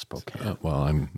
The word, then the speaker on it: Spokane: Which is Spokane. It's pronounced Spokane. – That's my Britishness coming out. Not knowing how to pronounce it. Is it Spokane or Spokane? – Spokane, yeah.